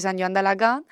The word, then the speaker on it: San Joan d'Alacant: Sí, esta vez nos hemos trasladado al campus de San Joan d'Alacant. – Qué buena pronunciación, ¿eh? – Sí.